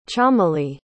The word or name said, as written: chameli